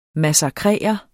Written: MASSAKRERE

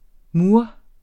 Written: mur

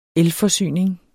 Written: elforsyning

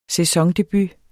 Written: sæsondebut

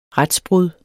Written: retsbrud

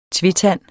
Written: tvetand

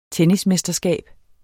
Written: tennismesterskab

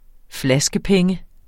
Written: flaskepenge

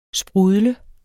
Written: sprudle

